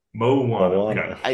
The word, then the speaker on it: Moana: Did you just combine Mulan and Mulana? – Moana. Is that right? Is that how you pronounce it, Lee? Moana. – You said yeah. Mulana. – Oh, did there's Mulan and there's Moana.